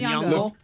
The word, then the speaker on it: Nyong'o: Nyong'o.